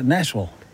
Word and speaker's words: Nashville: Yeah, Nashville. – You know, that's actually closer to how a local would say it. Anything that has a ville at the end, they say vol, like Louisville, Kentucky.